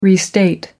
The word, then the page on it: restate: American English